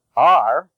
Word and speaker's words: are: R, A-R-E, rhymes with car. Have, H-A-V-E, although it is in the cave-save spelling family, is pronounced have.